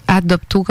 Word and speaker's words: Adopto: Adopto, comme ça se prononce? – Oui, exact.